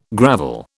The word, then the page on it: gravel: British English